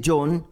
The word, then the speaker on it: John: Y si usted desea tener más reflexiones mías en audio o en video, puede ir a mi canal en YouTube, Padre John Mario Montoya. O mi página Facebook, Padre John Mario. Solamente que ese John se escribe J-O-H-N.